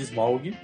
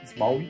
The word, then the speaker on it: Smaug: E para finalizar 2013 com chave de platina, temos o Hobbit, Desolação de Smaug. Smaug, desculpe, falei errado, Smaug.